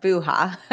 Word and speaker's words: Buha: Buha.